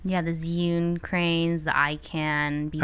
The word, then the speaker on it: Zhiyun: I say Zhiyun. – I hear a lot. – Zhiyun, but they say it like Zhiyun. – They say like Zhiyun. – Zhiyun. But yeah, people say Zion. James says Zion. That's totally off.